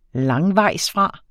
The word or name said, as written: langvejsfra